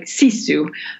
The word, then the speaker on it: sisu: Well, in Finnish, it would be pronounced more or less like sisu, but